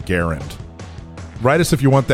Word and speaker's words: Garand: The guy himself pronounced his last name Garand, but everyone says Garand, so whatever you want to say.